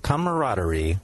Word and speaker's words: camaraderie: There is another syllable in there. Camaraderie. – How come nobody says that? – No one says it. Everyone says it like Russians.